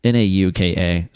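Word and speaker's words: Nauka (Наука): I think it's Nauka is how I would pronounce it. No one understands the Cyrillic alphabet, including Russians, but it's Nauka.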